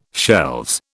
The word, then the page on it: shelves: British English